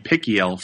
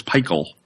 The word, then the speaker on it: Peichel: I was saying Pickiel for a long time, so it's Peichel. Like Michael.